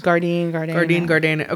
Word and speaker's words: Gardein: Are the Gardein. Is that the way you pronounce it? Gardein breakfast patties? – Gardein.